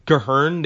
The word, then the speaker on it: Gehirn: Like the way they pronounce certain words, like Gehirn, they pronounce with the German inflection because it's a German word.